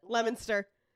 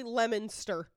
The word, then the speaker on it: Leominster: You're gonna laugh, Leominster. – No. Leominster. – Leominster. – It's literally Leominster. – Leominster. – Yeah. – Leominster. Okay, so the O doesn't say shit.